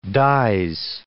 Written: dies

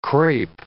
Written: crape

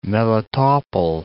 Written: Melitopol

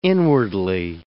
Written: inwardly

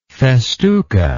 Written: festuca

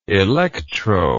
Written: electro

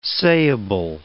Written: sayable

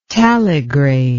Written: taligrade

